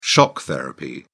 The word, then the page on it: shock therapy: British English